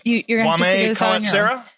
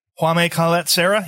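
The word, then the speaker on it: Jaume Collet-Serra: It is Juame Colette Serra. – I'm going to say. – Say it again. – Juame Colette Serra.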